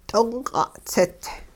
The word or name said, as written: d'ongq'a'-tsit